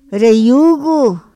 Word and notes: ra yugu: Otomí

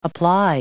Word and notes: apply: verb

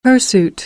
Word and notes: hirsute: American English; uː

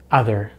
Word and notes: other: American English

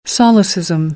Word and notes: solecism: American English; ɑː

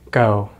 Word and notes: go: American English

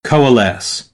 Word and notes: coalesce: American English; oʊ

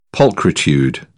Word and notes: pulchritude: British English; ʧ

